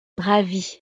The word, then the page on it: ravie: frances